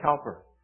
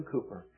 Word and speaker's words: Cowper: One of the great hymn writers, William Cowper, looks like Cowper, but the English pronounce it William Cowper.